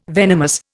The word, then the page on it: venomous: Indian